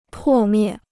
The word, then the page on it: 破灭: pò miè